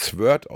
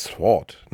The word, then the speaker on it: Sword: Also, ich habe früher mal das Schwert auf Englisch mit Sword ausgesprochen, aber es heißt Sword, ne? Sword. Ja.